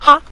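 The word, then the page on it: ha: tone 5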